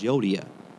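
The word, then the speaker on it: Euodia: It was an actual person in the Bible that Paul was referring to, and that's how you pronounce this person's name was Yodia. It's spelled E-U.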